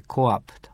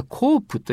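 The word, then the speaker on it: co-op: あれは COOP と書いてコーアップと。ね。日本ではコープと言ってるかもしれません。アメリカでは、あるいは英語の発音でコーアップと言います。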